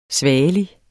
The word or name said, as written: svagelig